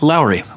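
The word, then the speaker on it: Lauri: How do you say it? – Lowry. – Lowry. Lowry. Is Lowry.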